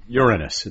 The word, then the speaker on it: Uranus: Uranus. It's pronounced Uranus.